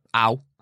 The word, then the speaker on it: Aue: How do you say that? – No, go on. Ow. Enlighten me. Ow.